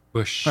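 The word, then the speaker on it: Busch: And it's B-U-S-C-H, spelled like the beer, not like the shrub. – Bush.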